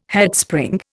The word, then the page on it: Headspring: Indian